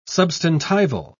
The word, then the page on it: substantival: British English